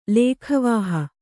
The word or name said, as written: ಲೇಖವಾಹ